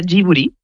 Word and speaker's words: Ghibli: En japonés se pronuncia Ghibli. Uh-huh. Porque ellos no tienen el sonido de la L, pero es una historia bastante graciosa porque el nombre lo elige Hayao Miyazaki y lo elige por la palabra japonés.